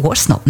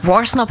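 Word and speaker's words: Worsnop: Stuff a Maximum Rock, Dark Thoughts, The Funeral Portrait featuring Danny Warsnop. – Ist richtig ausgesprochen? Warsnop, ne? – Warsnop hätte ich jetzt auch gesagt, aber ich finde diesen Anfang so mega.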